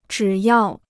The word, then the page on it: 只要: zhǐ yào